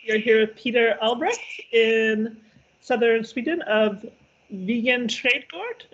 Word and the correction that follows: Vegan Trädgården: Am I saying it correctly? – Yeah, Vegan Trädgården. Yes. – Vegan Trädgården.